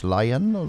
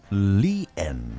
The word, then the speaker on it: Lian: Geschrieben tatsächlich L-I-A-N, also müsste man eigentlich sagen Lian, ja, wie es aber im Englischen so ausgedrückt wird, aber hier möchte sie anders genannt werden, ja, nicht Lian, sondern Lien möchte sie ausgesprochen werden.